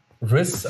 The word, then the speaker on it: rizz: Aber Riz, als Englisch ausgesprochen, gibt es meiner Meinung nach nicht. – R-I-Z-Z. – Riz.